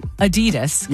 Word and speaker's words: Adidas: I have heard people call it Adidas, not Adidas. – All right. Well, anyway, Adidas, because that's how you say it.